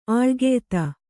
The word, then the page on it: ಆಳ್ಗೆಯ್ತ: āḷgeyta